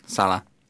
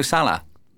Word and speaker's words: Salah: Mo Salah. – Salah, okay. Sorry, I thought you were saying that's not how it Salah. – Was. – Salah. No, Salah. What's wrong with you? Right. Until he turns around and he says, everybody's getting my name wrong, it's Salah.